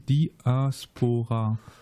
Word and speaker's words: Diaspora: äh, ich würde nämlich sagen Di Diaspora. – Diaspora, genau. – Mhm. Ähm, Diaspora, also laut Duden Diaspora. Mit einer Betonung auf dem A.